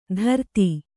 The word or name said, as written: ಧೃತಿ